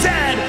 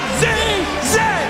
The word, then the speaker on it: Zed: And it is pronounced Zed, not Z-Z.